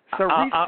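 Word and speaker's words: Saoirse: It's Cerise Ronan.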